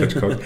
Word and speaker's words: Hitchcock: Also gerade bei Alfred Hitchcock, man muss das britisch aussprechen, bei Alfred Hitchcock,